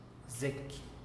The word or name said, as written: zeky